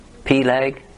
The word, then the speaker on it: Peleg: When Peleg, is that how you pronounce that? Mm-hmm. Peleg.